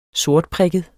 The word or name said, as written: sortprikket